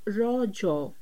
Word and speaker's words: Rajo: The festival is natively pronounced as rojo, a local term that means menstruating woman.